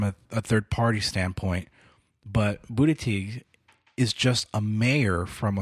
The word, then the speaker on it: Buttigieg: Of the new people, and even this, I think his name's pronounced Buttigieg.